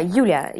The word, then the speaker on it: Yulia: How do you pronounce it again? Yulia? – Ah, the character. Yeah, Yulia. Yulia.